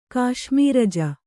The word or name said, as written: ಕಾಶ್ಮೀರಜ